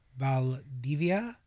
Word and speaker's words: Valdivia: Now, I recently read a piece from, I pray I do not butcher her name, Valdivia, V-A-L-D-I-V-I-A. – Okay, Valdivia. I would say so, yeah. – That's how you pronounce it.